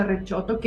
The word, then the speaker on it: Recioto: rechoto, no te rías, se escribe, se escribe Recioto, pero se pronuncia rechoto,